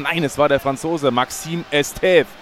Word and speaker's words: Maxime Estève: Aber es war Maxime Estève. Nico hat völlig recht. Mein Französisch war schon ein bisschen eingerostet. Also ja. So wird der Kollege, den sie aus Burnley geholt haben, ausgesprochen.